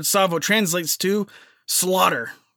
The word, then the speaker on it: Tsavo: So let's start with Tsavo. Tsavo is spelt T-S-A-V-O, so the T is silent.